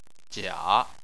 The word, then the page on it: jia3: chinese